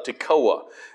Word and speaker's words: Tekoa: The words of Amos, who was among the herdmen of Tekoa. It's spelled like Tekoa, Georgia, but it's pronounced like Tekoa, Georgia, but it's spelled T-E-K-O-A, but it pronounce it just like Tekoa.